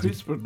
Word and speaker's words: CRISPR: CRISPR. – Ee, doğru mu telaffuz ediyorum? – Hocam? CRISPR doğru telaffuz ediyorsunuz. Aslında biraz İngilizce telaffuz oluyor zaten bu ama dilimize yatkın bir telaffuz e, gen düzenleme veya şimdiye kadar halk tabiriyle bilinen genetik mühendislik teknolojisi.